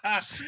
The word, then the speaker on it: SNES: Sent for my SNES rhymes with not mess. – I hate you gotta say Alejandro. It. – SNES.